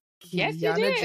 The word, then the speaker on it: Kiiyonna: Kiiyonna Jones.